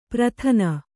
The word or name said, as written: ಪ್ರಥನ